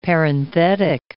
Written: parenthetic

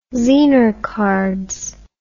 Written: zener cards

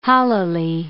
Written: hollowly

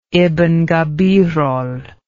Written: ibn gabirol

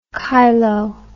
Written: chylo

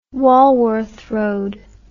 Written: Walworth Road

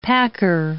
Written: packer

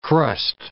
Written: crust

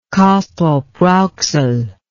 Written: Castrop-Rauxel

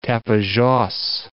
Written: Tapajoz

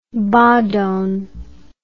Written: Bhadon

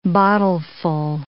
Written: bottleful

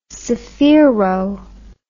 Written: sphaero

